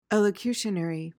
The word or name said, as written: elocutionary